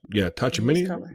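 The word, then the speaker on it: Tchouaméni: I said Los Negros is really about to go down because the president Tachamani. How you say his name? – Yeah, Tachamini.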